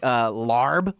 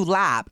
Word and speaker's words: larb: Some of the best shit, if you like larb on the menu, lap is, you know, actually how it's pronounced.